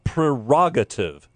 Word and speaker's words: prerogative: It is prerogative. P-R-E. – Well, everybody pronounces that one wrong. – Prerogative. It's pre. P-R-E. Prerogative. – Nobody pronounces the first R. – I know. I'm just helping you out. – Prerogative, because pre means before.